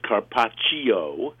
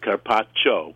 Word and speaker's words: carpaccio: Now, I know a lot of people call it carpaccio, but the correct pronunciation is carpaccio, because C-I-O in Italian is cho.